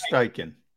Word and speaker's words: Steichen: is it Steichel? – Am I pronouncing that? Steichen. – Steichen.